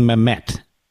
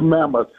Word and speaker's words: Mamet: What am I saying, Mamet? That's the French pronunciation. – No, I'm just kidding. – It's Mamet. It's like Jack Lee Bissett. Mamet. It's Mamet.